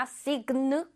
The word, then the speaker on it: sign: 여기 sign이 바로 sign이에요. 이거가 G가 그 소리가 나잖아. signal 아닙니다. sign이라고 읽혀요. sign. Alright.